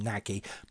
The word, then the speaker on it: Nagy: I had the great fortune to teach someone from Hungary once who explained to me how to pronounce it, because for ages I've been calling him Nagy, but it's Nagy, apparently.